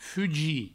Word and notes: fugir: fugi

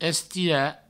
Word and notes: estirar: Provençal; estira

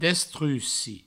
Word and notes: destruci: destrussi; Provençal

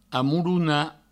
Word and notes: amolonar: amoulouna

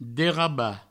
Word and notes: derrabar: Provençal; derraba

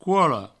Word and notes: cola: couolo

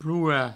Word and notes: plorar: ploura

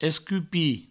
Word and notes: escupir: provençal; escupi

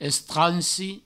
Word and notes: estransi: Provençal

